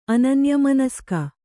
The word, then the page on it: ಅನನ್ಯಮನಸ್ಕ: ananyamanaska